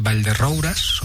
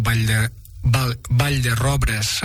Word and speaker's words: Vall de Roures: El cap de la comarca és Vall de Roures, o Vall de Robres, amb la pronunciació local.